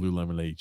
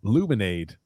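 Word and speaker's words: Lululemon: No, Tony G, she's not been drinking Lou Lemonade. She does not seem like she's pro Lou Lemonade. – Luminade.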